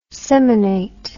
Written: seminate

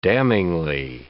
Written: damningly

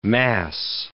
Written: mass

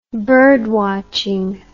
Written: birdwatching

